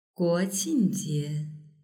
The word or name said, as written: guoqingjie